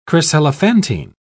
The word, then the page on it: chryselephantine: British English